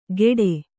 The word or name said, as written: ಕೆಡೆ